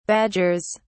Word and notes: badgers: British English

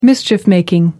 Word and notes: mischief-making: American English

